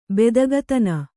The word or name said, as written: ಬೆದಗತನ